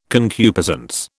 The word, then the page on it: concupiscence: British English